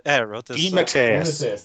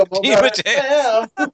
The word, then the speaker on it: DeMatteis: DeMatteis. – There you go, DeMatteis.